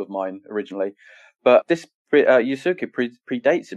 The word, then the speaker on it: Yasuke: The Incredible Story of Yasuki, and I hope I'm pronouncing that correctly. – Yep, it's good.